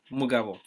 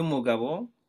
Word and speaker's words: Mugabo: Mugabo. Now, there is that syllable that is like the soft B, wo versus bo. But both are good. – And the full name? – Yes. Thierry Mugabo, Uwilingi Imana.